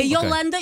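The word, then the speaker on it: iolanda: É Yolanda e o I é minúsculo. – Ok. – Por isso, é a nossa vencedora do Festival da Canção, temos de decorar como é que se escreve e como é que se diz.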